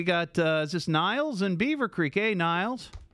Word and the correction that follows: Niels: Hey, it's Niels. – Oh, Niels. I'm sorry, Niels. Yeah. Mispronounced that.